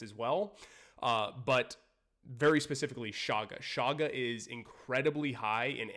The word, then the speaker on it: chaga: And oh, one last thing I did not talk about enough was shaga. So the shaga mushroom, the medicinal mushroom shaga it's spelled C-H-A-G-A.